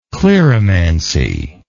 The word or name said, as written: cleromancy